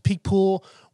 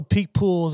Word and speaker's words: Picpoul: Also, Picpoul is a kind of cool name. It means lip stinger. And the way it's pronounced, the way it's spelled is P-I-C-P-O-U-L.